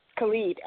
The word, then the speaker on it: Khalid: Khalid.